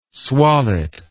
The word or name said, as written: swallet